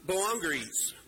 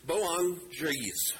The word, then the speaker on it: Boanerges: To them he gave the nickname um, Boanerges. Boanerges. Oh my goodness. Boanerges.